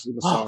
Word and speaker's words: Massaro: Ashley Massaro.